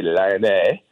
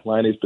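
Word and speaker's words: Lyonnaise: In France, you'd probably say Lyonnais, downtown. Well, how would I say it? – Yeah, in Antoine, you got Lyonese potatoes. Yeah, Lyonese potatoes. – Lyonese potatoes. Got it. – Get a little more nasal, Tommy.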